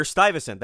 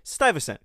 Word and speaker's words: Stuyvesant: New Yorkers will say Peter Stuyvesant. That's what I grew up with. However, I heard Charles Gehring, who is the know-all and end-all of the New Netherland Institute, and he pronounces it, probably in perfect 17th century Dutch, as something close to Stuyvesant, or Stuyvesant, something like that.